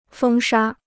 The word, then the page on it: 风沙: fēng shā